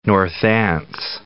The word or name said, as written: Northants